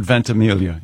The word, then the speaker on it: Ventimiglia: Is it Ventimiglia? – Does he go with the soft G or does he do the Mike Birbiglia thing? – I have heard the soft G. – I've heard Ventimiglia, yes.